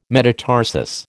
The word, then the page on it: metatarsus: American English